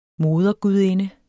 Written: modergudinde

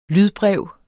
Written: lydbrev

